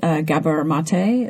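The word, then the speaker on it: Gabor Maté: You mentioned Gabor Mate.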